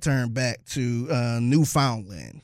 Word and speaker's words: Newfoundland: But I think technically it's like Newfoundland. – That's how you pronounce it.